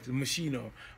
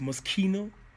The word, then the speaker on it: Moschino: Because when you lift your arms To those who don't know what he said, he just pronounced it right, Machino. But we know it as Moschino. So, it's Moschino.